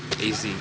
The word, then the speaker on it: AZ: My name's AZ. – AZ? – Yeah. – How do you spell it? – AZ.